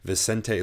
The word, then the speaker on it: Vicente: He's going to fight, you know, Vincent Luque or whoever. Vicente Luque, that's how you pronounce it.